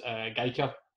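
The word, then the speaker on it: Gaizka: Delighted to have such a guest as Gaika. Keep me right there, Scott. I think my pronunciation is okay. – Spot on.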